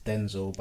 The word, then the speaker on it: Dxnzil: Yeah, so on socials, um, on Twitter and Instagram, um, it's Denzel, but it's spelled D X N Z I L.